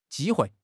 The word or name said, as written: ji1 hui4